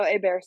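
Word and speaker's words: Héber: So effectively the H is silent in a very Spanishy kind of way. So Ebar, yeah.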